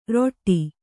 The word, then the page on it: ರೊಟ್ಟಿ: Kannada